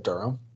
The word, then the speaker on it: Durham: He's going to be in Durham, North Carolina on October. Is that how you say it? Durham? – Yeah.